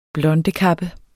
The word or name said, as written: blondekappe